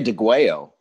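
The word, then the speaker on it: Degüello: And so what I love about this album, okay, for starters, it's DeGuello. Is that how you say it? – I say DeGuello.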